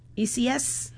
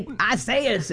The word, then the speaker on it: Isaias: when I see that, and they've been, you know, Jeanette to the street, what's her name, Jeanette Collier, she's been saying ECS, right? Which is, I guess, the right way to say it.